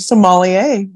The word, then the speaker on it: sommelier: Okay, so you said it was an elite job to be how do you say that word? – Sommelier, right? Sommelier. Is that how you say it? – Yeah.